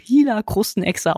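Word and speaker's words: Gila-Krustenechse: Diese Chila-Krustenechse übrigens, wird die eigentlich Chila-Krustenechse geschrieben mit einem G vorne, aber Chila-Krustenechse ausgesprochen, also ein bisschen anders.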